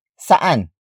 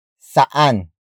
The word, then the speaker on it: saan: We will continue our lesson about saan after this break. Welcome back to Go Filipino. Let's continue our lesson about the Tagalog word for where, which is saan. Saan. Saan. Saan is spelled as S-A-A-N.